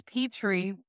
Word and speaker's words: Petrie: It's Petrie, like the land.